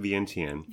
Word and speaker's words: Vientiane: We're talking about Vientiane, but we pronounce it Vientiane.